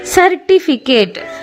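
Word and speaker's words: certificate: ಸರ್ಟಿಫಿಕೇಟ್ ನೋಡಿ, ಇಲ್ಲಿ ಸಹ ಎರಡು ಸಲ ಸಿ ಬಂದಿದೆ, ಆದರೆ ಎರಡು ಬೇರೆ ರೀತಿಯ ಉಚ್ಚಾರ ಕೊಡ್ತಾ ಇದೆ. C E R T I F I C A T E, C E ಇದಿರೋದಕ್ಕೆ ಅಲ್ಲಿ ಸ ಬಂತು. ಫಿಕೇಟ್, ಅಲ್ಲಿ ಸಿ ಮುಂದೆ ಎ ಅಕ್ಷರ ಬಂದಿದ್ದಕ್ಕೆ ಸ ಕ ಉಚ್ಚಾರ ಆಯಿತು, ಸರ್ಟಿಫಿಕೇಟ್.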